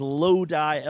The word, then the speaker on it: Lodi: It is Lodi, L-O-D-I.